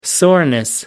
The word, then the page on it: soreness: British English